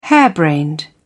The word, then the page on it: harebrained: British English